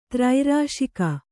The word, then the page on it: ತ್ರೈರಾಶಿಕ: Kannada